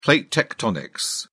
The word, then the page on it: plate tectonics: British English